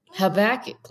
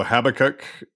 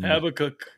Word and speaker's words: Habakkuk: How would you pronounce it? – Habakkuk, like you just said. – Okay. – Well done. – I think there's also Habakkuk and. – Habakkuk, yeah.